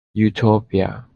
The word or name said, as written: Utopia